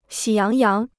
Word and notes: 喜洋洋: xǐ yáng yáng

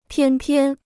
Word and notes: 偏偏: piān piān

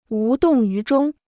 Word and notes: 无动于衷: wú dòng yú zhōng

